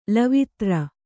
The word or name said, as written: ಲವಿತ್ರ